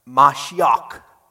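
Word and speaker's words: Mashiach (משיח): Okay, now the word Messiah is Hebrew. How's it pronounced? Mashiach. Nice? Sounds like Klingon, right?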